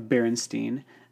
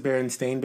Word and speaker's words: Berenstain: But how come so many people in school, where pronunciation is part of what we're doing, pronounce it Berenstain as opposed to Berenstain? That's a very clear, you know, so when you look at it, you're like, Berenstain, that's not right.